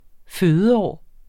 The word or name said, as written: fødeår